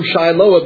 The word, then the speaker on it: Shiloh: And that term Shiloh, that's the Hebrew interpretation. Siloam is the Greek interpretation. Or the Greek pronunciation, rather.